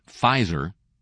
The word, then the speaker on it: Pfizer: And in the last couple of weeks, Pfizer, a drug company so big it has a silent P in its name, agreed to pay a $2.3 billion fine to settle civil and criminal allegations that it had allegedly marketed its painkiller Bextra.